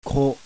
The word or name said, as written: KO